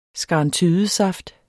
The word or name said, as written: SKARNTYDESAFT